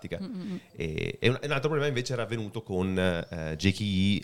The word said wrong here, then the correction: GKE: e il JKE, non si dice così, GKE, più facile.